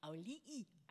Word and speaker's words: Auli'i: Auli'i, I finally learned how to say her name.